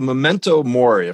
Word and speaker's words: Memento Mori: I was really, the Memento Mori, if I'm pronouncing that correctly.